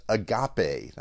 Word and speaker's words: agape: Now the Greek word that's used for love here is agape.